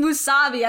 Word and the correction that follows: musubi: and I know it's not right. Musubi. It just takes a while.